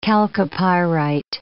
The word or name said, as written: chalcopyrite